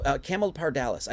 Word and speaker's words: Camelopardalis: Camelopardus is a giraffe. So, Camelopardalis. I keep saying this incorrectly, so I apologize. Camelopardalis.